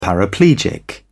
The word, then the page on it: paraplegic: British English